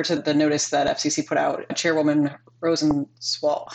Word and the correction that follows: Rosenworcel: Rosenworcel? – I apologize to her.